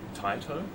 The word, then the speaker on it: Taito: It's actually pronounced Taito. – Oh, Taito. – Yeah. – Sorry. – Don't be other pronunciation's fine. – It's not what Japanese people say.